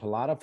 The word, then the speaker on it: Pelada: I want to tell everybody that it's called Pilata Football Academy, and it's spelled P-E-L-A-D-A.